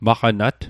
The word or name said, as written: maqanat